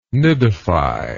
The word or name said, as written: nidify